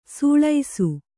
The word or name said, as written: ಸೊಳಸ